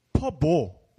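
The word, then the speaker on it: phở: 특히나 그 후회 음식 중에 가장 대표적인 게 여러분들 그 동남아시아의 그 특히 베트남의 쌀국수라 그러면 퍼 많이 아시죠? 퍼. 네. 퍼. 뭐 이렇게 맞아요. 우리가 아는 그 포호와 네. 무슨 포호. – 네. 포호할 때 사실 있잖아요. 그 발음이 네. 퍼입니다. 퍼.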